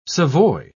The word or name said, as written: savoy